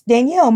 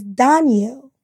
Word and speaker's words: Danielle: So my middle name, I always pronounce Danielle. My mom said it's Danielle.